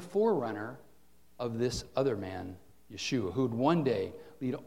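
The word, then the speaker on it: Yeshua (ישוע): The Hebrew pronunciation of Joshua is Yeshua.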